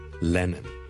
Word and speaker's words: Lennon: John Lennon。 John Lennon。 John Lennon, 一个英国的很出名的歌手和作曲家。 Lennon。 L-E-N-N-O-N。第一个是 L 音，所以不要将它读成 N 音，不是 Lennon, 是 Lennon。